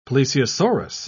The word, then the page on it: plesiosaurus: British English